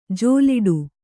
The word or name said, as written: ಜಲ್ಲಡೆ